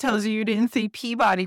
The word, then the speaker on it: Peabody: Only you Massachusetts people say Peabody.